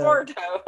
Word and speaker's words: Laborteaux: That, I mean, here in Louisiana, that T-E-A-U-X is pronounced toe. – So it's labor like toe. – Laborto or something like that. – Laborto.